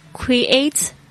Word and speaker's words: create: Next one, create joyfully。 create 这个字呢 C-R-E-A-T-E，注意一下它的发音，不是 create，是 create。